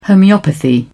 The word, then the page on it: homeopathy: British English